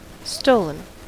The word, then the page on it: stolen: American English